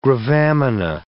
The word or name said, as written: gravamina